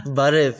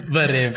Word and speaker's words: Barev: How do you say hello again? – Barev. – Barev.